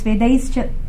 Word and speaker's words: Vedaischa: That, you know, that verse, Vedaischa, it's actually it's pronounced as Cha.